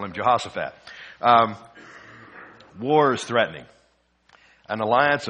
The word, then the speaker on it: Jehoshaphat: I'm going to just pronounce it the way I learned it as a kid, and that's as a Jehoshaphat instead of Shaphat. In Hebrew, the difference between S and Sh is one dot either here or here.